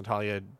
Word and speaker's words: Natalia: So remember I mentioned I really find that Natalie, I found out it's actually pronounced Natalia Dwyer.